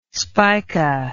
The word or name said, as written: spica